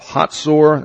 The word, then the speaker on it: Hazor: He also built up Hazor, that's the way they pronounce it, Megiddo, and Gezer.